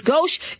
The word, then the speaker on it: gauche: I will give you gauche, because gauche is pronounced gauche, but it's spelled G-A-U-C-H-E.